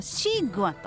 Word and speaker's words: 西瓜: ちなみに中国語ではこのスイカをシーグアと発音する